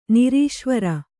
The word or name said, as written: ನಿರೀಶ್ವರ